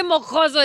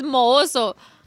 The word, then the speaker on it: mohoso: Y no se dice mojoso, es mohoso.